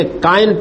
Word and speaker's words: کائن: اس کے کائن پیدا ہوا، یہ قدیم تلفظ ہے قابیل